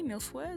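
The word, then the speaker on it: mille-feuille: There was a chocolate milfoy. Milfoy, is that how you pronounce it? – Milfoy, yep.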